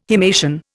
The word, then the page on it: himation: Indian